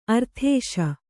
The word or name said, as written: ಅರದೇಸಿ